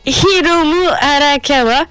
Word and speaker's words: Hiromu Arakawa: Par contre, il est vrai que cette année, si je retrouve l'information dans mes feuilles, Himuru Aka Arakawa, je sais pas comment ça se prononce, c'est abusé. Hiromu Arakawa, c'est la mangaka de Full Metal Alchemist, en effet, qui vient à Japan Expo cette année, qui n'est pas invité d'honneur.